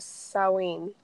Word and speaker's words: Samhain: apparently the proper way to say it is Samhain. Because oh. it's like a it's like a Celtic, Irish, European type of language.